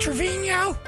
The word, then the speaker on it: Trevino: Is that how you say it, Trevino? – Yes. – Yeah, that's the Spanish pronunciation.